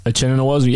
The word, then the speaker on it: Uchenna Nwosu: Anyways, the Patriots at 63, Uchenna Nwosu from USC. Did I say that right? – Uchenna Nwosu, yeah.